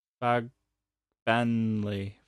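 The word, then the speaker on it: Fagbenle: No, it's not an I, it's an L. Fagbenley.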